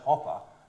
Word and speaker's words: Hopper: Like, you say Hopper, and so a reality of living in my family was having a name spelled incorrectly, so that now when I say my name, I say Hopper as in grasshopper, and everyone has a little chuckle, and they still don't get it.